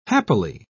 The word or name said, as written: happily